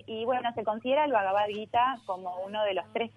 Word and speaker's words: Bhagavad Gita: No, Bhagavad Gita.